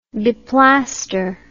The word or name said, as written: beplaster